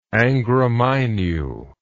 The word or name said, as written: Angra Mainyu